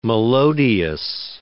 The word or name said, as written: melodious